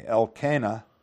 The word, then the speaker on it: Elkanah: "There was a certain man of," whatever it is, "of Mount Ephraim, and his name was Elkanah," or Elkanah. So I guess it's a long A, Elkanah, "the son of Jehoram, the son of Elihu, the son of Tohu, the son of Zuph, an Ephrathite.